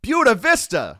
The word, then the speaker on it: Buena Vista: Looking alive in the five, Buena Vista. Pronunciation 101. – Buena Vista. – Mona Belt. – Buena Vista. – Gallardi. – Muhlenberg. – Worcester. – Buena Vista. – Buena, not Buena. They sure looked lively on Saturday once they caught a bit of a break.